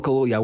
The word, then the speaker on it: Kaluuya: Daniel Kaluuya, is that how you pronounce his name, by the way? Yeah. Daniel, right, thank you. Daniel Kaluuya, wow.